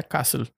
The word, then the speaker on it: castle: Se escribe castle, pero se pronuncia castle, no se pronuncian la, la T ni la E final.